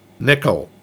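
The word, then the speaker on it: Nicol: Nickel Williamson's name is, in fact, pronounced nickel. My sides. – That's the first time I ever heard that. I thought it was Nicole, but... – I looked it up, and it is pronounced nickel.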